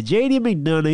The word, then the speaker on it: J.D. McDonough: Yeah, J.D. McDonough, he comes out, interrupts, because he's, of course, trying to audition for the Judgment Day.